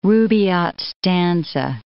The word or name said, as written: Rubaiyat stanza